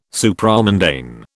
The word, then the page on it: Supramundane: British English